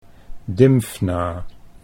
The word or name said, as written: Dymfna